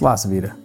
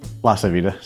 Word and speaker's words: LaCivita: It's LaCivita. – LaCivita, sorry.